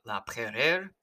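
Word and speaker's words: La Peyrère: I realize in French it's pronounced something closer to Isaac Laperere, but I am not that good at speaking French, so I'm going to go with a pronunciation I think I can hit pretty close to every time.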